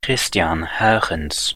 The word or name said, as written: Christian Huygens